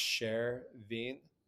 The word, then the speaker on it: Chervin: So my name is pronounced Chervin.